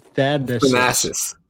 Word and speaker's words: Thanasis: Thanasis.